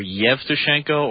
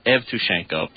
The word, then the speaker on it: Yevtushenko: It's now, the thing that's confusing, the way the Cyrillic and the Roman alphabets transliterate, he could be listed under Y for Yevtushenko or E for Evtushenko, because the E sound in Russian is sometimes pronounced Yeh and sometimes pronounced Eh, but it's an E. It's written as an E.